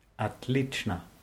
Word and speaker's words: Отлично: Отлично. – Das Wort schreibt man mit O am Anfang, wird aber mit A ausgesprochen. Отлично.